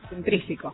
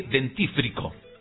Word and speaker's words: dentífrico: Pero por una cuestión de fonética, suena mejor dentrífico que dentífrico.